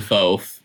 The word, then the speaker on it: FOAF: I pronounce it FOAF. Yeah, I pronounce it FOAF. Right. I heard it pronounced FOAF. It's a fun way to say it.